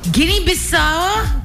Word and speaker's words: Guinea-Bissau: Guinea-Bissau. Bissau. – Or something like that. – B-I-S-S-A-U.